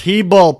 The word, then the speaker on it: Hebel: It's pronounced Hebel, people. They told me. They corrected me.